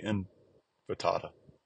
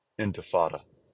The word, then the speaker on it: intifada: intifada." I didn't say that right. Intifada. There we go.